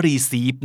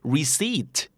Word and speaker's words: receipt: receive นะครับอ่านว่า receipt